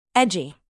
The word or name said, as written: edgy